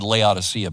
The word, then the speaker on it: Laodicea: I'm going to let you kind of receive it, engage, put yourself in the position of these Laodiceans. Before I read it, how many of you have ever heard the word Laodicea before, right? It's actually probably pronounced Laodicea, but nonetheless, we've probably heard of Laodicea.